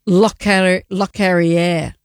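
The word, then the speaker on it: Locorriere: His Lockery. He has passed away. Let's say his name correctly, she said, looking for the piece of paper. Lockerier.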